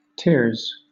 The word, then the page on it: tiers: British English